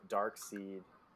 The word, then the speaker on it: Darkseid: It's pronounced Darkseid. – Darkseid. – I used to do the same thing. I'd always say Darkseid. But then I saw one of the cartoons where they call him Darkseid.